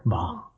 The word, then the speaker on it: Bong: And the winner went to Parasite, Director Bong.